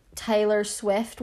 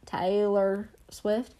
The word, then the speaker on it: Taylor Swift: Ariana Grande is still under the same record label that Taylor Swift was on. Why did I say her name like that? Taylor Swift.